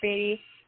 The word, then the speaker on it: Beattie: I actually go by both. Paige Beattie or Paige Beattie. It really, my mom used to say Beattie, my dad says Beattie.